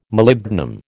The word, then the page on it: Molybdenum: American English